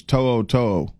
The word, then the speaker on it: To'o To'o: Well, I don't think you said his name right, and I don't usually say it right, but I believe it's To'o To'o.